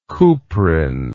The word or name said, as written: kuprin